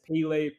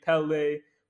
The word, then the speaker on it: Pelé: But one name that everyone knows, whether they pronounce it Pele, Pelé, Polo, or whatever they pronounce it, I think Pelé, when it comes to the term greatness, has to be right up there at the top.